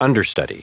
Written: understudy

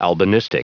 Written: albinistic

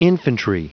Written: infantry

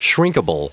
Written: shrinkable

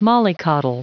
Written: mollycoddle

